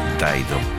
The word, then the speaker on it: Dido: Se escribe Dido, pero la pronunciación en inglés es Daido.